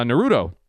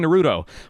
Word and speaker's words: Naruto: Like, here's the one I can think of right now is uh, Naruto. So I was saying Naruto, but it's Naruto.